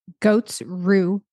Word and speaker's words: Goat's rue: Goat's rue. Goats, like a goat with an apostrophe, S-R-U-E.